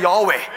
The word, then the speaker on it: Yahweh: This is why Yahweh should not be said with a tongue. You have to say, it's like a breathing. Who remembered I said that? This is how you say Yahweh.